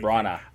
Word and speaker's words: Branagh: Branagh.